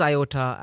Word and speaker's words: IOTA: What is IOTA and what kind of stuff do you work on as the head of telco and infrastructure development? – Yeah, no, thank you. You actually said it right.